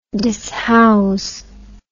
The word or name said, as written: dishouse